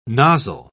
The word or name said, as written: nozzle